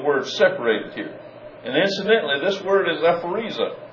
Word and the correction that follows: aphorizo: Now, I haven't pronounced it correctly, Matt. It's a form. It's a Greek form, aphorizo.